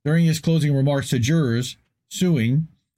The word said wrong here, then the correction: Siung: like literally, Siung, I butchered the names.